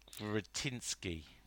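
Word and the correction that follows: Křetínský: Go on, you say his name. – I think it's Kretinsky.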